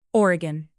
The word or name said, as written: oregon